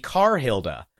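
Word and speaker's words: Karhilda: Is that the English R, the non-rhotic R? And yeah, it was. So her name is actually Carhilda. – Yeah. – And I have been saying it wrong this entire time.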